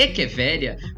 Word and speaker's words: Echeveria: Por exemplo, Eche Echeveria é uma palavra que é muito comum no cultivo de plantas, mas que agora as pessoas sabem que a pronúncia é Echeveria.